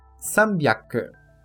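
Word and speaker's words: sambyaku (三百): A leitura byaku neste kanji é exclusiva para uso na pronúncia do número 300, que é sambyaku.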